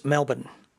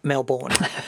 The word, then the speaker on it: Melbourne: Just a pro tip there, it's Melbourne. Not Melbourne. Melbourne.